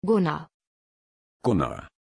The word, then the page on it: gunnar: German